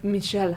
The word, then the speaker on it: Michel: It's like Michel Haneke.